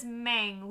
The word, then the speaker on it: Meng: but her name is Meng, which she says rhymes with Aang, and it doesn't really, but okay.